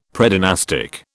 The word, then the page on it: Predynastic: British English